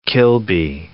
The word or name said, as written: Kilby